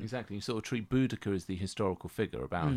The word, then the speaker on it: Boudicca: So, what you were, I mean, we learned how to pronounce Boudicca, of course, not Boadicea. – That was my favourite bit, though, because the way it transpires that there's two ways of pronouncing the name and two very different functions that those names serve.